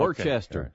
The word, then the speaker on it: Worcester: I know it's spelled like that. – Yeah. – Yeah. – Worcester. – Okay. It's yeah. spelled like a three-syllable word, yeah. but it's Worcester.